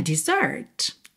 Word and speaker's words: dessert: Mas não muda a sua pronúncia, de Z para S. Você não fala dessert, é mesmo dessert.